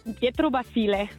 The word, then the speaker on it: Pietro Basile: Mit Pietro Basile.